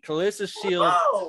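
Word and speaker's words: Claressa Shields: Say her name correctly. – Okay. Let me repeat. Kalissa Shields, the GOAT, versus Savannah Marshall, the silent assassin for undisputed female middleweight.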